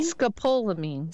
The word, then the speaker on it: scopolamine: Scopolamine.